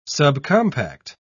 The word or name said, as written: subcompact